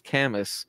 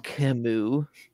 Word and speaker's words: Camus: That's just how I read it, was Camus, and I got so shamed. It's Camus.